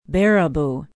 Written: baraboo

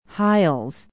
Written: hiles

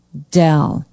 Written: dell

